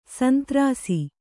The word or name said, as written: ಶಾಂತರಸ